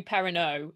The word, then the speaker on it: Perrinot: In a French monastery, two herds of pigs grew agitated and killed a man called, I believe it's Perrinoir Mouet, but it's spelled Perrinot Mouet. So, you know, but with the French, I would imagine Perrinoir. – It would be Perrineau, essentially, Perrineau. In an English accent.